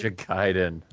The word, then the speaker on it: Ninja Gaiden: Ninja Gaiden.